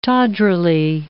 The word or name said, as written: tawdrily